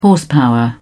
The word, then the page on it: horsepower: British English